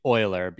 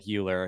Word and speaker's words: Euler: Well, technically Euler, even though it looks like Euler. It's after that. Yeah. You look up the German pronunciation.